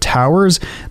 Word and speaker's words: TTWWRRSS: Right now, we're going to hear from a band called Towers that is spelled T-T-W-W-R-R-S-S. So double the T, double the W, double the R, and double the S.